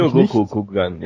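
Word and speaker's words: Ryōgoku Kokugikan: Das irgendwie richtig ausgesprochen, vermutlich Ryogoku Kokugigan, ja genau, ist richtig.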